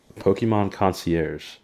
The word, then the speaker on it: concierge: That is Pokemon Concierge. I don't know if there I'm saying that right. How do you say the word? Concierge? – Yeah, you got it right the first time.